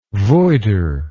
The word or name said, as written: voider